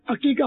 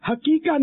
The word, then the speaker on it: عقیقہ: عقیقہ ہو جائے گا. یہ عقیقے کا تلفظ ہے نا وہ عین ہے، ہاں نہیں ہے، حقیقہ نہیں ہے، عقیقہ.